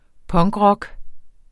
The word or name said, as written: punkrock